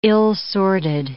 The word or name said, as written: ill-sorted